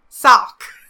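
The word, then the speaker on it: Saoq: As a human, the closest you can come is to pronounce sow to rhyme with cow, and then ok to rhyme with hawk. Then blend it together into one syllable. Sock.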